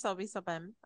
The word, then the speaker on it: Lobisomem: Something there with your Lobisomem. – With the man's wolf. Sure, I'm pronouncing it wrong. – No, but... I think you got it. I think it's Lobisomem. I think you got it right.